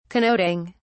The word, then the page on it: connoting: British English